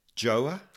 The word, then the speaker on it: Gjøa: Um, now, Martin, I'm just going to check with you whether you think that I'm pronouncing the name of the boat correctly. Gjøa? That's pretty good.